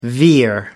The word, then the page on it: veer: American English